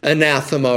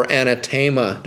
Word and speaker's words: anathema: Such had taught such things were under the ban of the church, the anathema or anathema, depending how you want to pronounce that. It can go both ways.